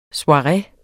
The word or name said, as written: soireer